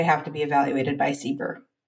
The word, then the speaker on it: CBER: Did you say CBER? – CBER. – How do you spell it? – C-B-E-R.